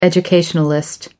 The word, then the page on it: educationalist: American English